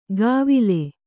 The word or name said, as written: ಗಾವಿಲೆ